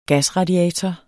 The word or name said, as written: gasradiator